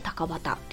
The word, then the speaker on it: 高畑: えー、場所がね、福岡県博多区にあるんですけども高畑遺跡高い低いの高に畑で高畑って読みます